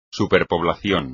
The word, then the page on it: superpoblación: espagnol